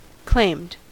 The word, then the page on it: claimed: American English